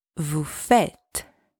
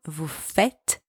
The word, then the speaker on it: faites: Second, make sure the S from vous faites is silent. It's not vous faites. It's at the end of the word, so it's silent. It's vous faites.